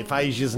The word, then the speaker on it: Feige: The pronunciation of Kevin Feige's name, apparently it's pronounced, and we now have it. It is pronounced Feige, hard G.